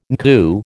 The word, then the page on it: NCU: American English